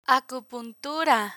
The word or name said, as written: acupuntura